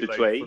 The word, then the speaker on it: Dutoit: Dutoit, D-U-T-O-I-T. – That's it. – Not Dutoit. – Well, see, in South Africa, you pronounce my name, Cobusdutoit, but that is very... – Dutoit? – Like, yeah, Dutoit.